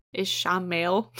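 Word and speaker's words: Ishamael: Compares her to Ishmael. I think that's his name. – Ishmael? – Ishmael. Because you just have to pronounce it not how it's spelled.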